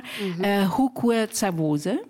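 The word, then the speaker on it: Hukwe Zawose: You have a famous, a legendary father, grandfather, Hukwe Tsavoze. – Yes. – Is it pronounced right? – Yes, you're right.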